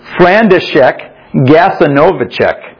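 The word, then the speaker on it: Franciszek Gajowniczek: And so names were called on one occasion, and a Polish Jew, a man named Frederick Gasnowicz, I'm sure I pronounced that wrong. Try again. Frandyszek Gasanowiczek was called, and as he was, he yelled out, he said, I've got a wife and a child.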